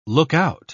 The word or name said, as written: lookout